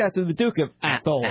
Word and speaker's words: Athol: Athol. – Athol. – A-T-H-O-L.